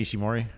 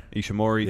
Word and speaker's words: Ishimori: He returned, attacked Will Ospreay, and he is, how do you pronounce his name? Taiji Ishimori. – Ishimori.